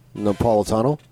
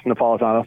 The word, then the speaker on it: Napolitano: Napolitano? – Yes, Napolitano. Thank you.